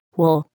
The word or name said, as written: wool